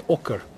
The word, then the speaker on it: Ocker: My name's Ocker.